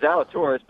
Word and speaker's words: Zalatoris: Real quick, I wanted to get his take on, and I think I always pronounce his name wrong, but Will Zalatoris, or is it Zalatoris? How do you pronounce that? – In fairness, it's Zalatoris, but